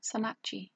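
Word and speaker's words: sannakji: I don't know if sun nudgee is how you say it. I was going to look it up. I didn't look it up. – Just how do you go with confidence. Spell it? – S-A-N, like sun. – Sun. – And N-A-K-J-I, and it's Korean.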